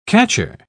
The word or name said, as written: catcher